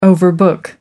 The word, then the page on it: overbook: American English